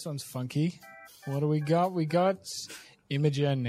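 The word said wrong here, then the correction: Imagen: in May 2022. Or Imagine. Apparently it's pronounced Imagine, which makes a lot more sense because, anything you can imagine, but I've been calling it Imogen.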